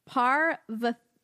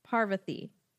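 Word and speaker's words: Parvati: And I phonetically spelled it for myself each time. Parvathi. Parvathi. Is how they said that character's name should be pronounced.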